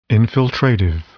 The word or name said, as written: infiltrative